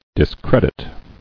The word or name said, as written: Discrediting